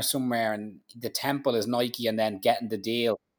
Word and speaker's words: Nike: When you think of Nike and you see his story, like, and he's selling Nikes out of the back. I called it Nike, but I remember now that in the book he says it's pronounced Nike.